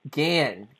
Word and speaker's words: Gahan: So we've got, how do you say her last name? Is it Gahan or Gahan? – It's Gan. – Gan, okay. – Gan. H okay. – Is silent.